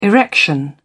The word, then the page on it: erection: British English